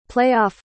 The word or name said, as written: play-off